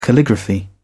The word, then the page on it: calligraphy: British English